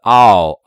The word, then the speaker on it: hour: 因为小时那个单词虽然它是以 h 这个辅音字母开头的，可是它并没有发音啊。这个单词它的第一个发音是 hour hour，它就是 hour hour 就是一种元音，所以元音前面用 an，所以应该说 an hour an hour 啊。